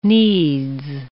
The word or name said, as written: needs